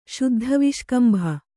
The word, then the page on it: ಶುದ್ಧವಿಷ್ಕಂಭ: śuddha viṣkambha